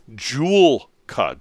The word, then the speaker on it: Joolca: Joolca. J-O-O-L-C-A.